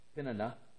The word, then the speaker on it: Peninnah: he would give portions to Peninnah," sorry, I pronounced that wrong, it's Peninnah,